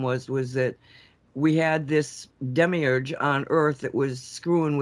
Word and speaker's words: Demiurge: But he was talking about the Demiurge. Did I say that right, Walt? That's why Walt. – Yes. The Demiurge.